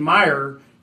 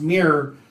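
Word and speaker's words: Myre: Family member, correct the pronunciation because we were saying Meyer, and it's not. It's pronounced Mirror, who was the hero football player, this and that.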